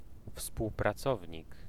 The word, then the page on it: collègue: [kɔ.lɛg]